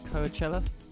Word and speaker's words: Coachella: Coachella, the festival, is that how you say it? Coachella? – Yeah, yeah, it is.